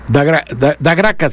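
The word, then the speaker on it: da Graça: Claro, da Gracias se escribe, pero como en Brasil hay un palito, una curvita en la parte baja, significa C. Da Gracias, bueno.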